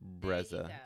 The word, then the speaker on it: Brezza: It was Brezza. – Brezza. Is that how you pronounce it? – Brezza, Brezza. – Brezza, Brezza. – B-R-E-Z-Z-A.